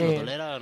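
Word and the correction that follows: Rodoreda: no, Rodurera.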